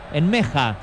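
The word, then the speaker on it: Nmecha: En Mecha, que si lo pronunciamos en el idioma original sería en Meja, ¿no? Más o menos así, por lo menos él se pronunció de esa manera.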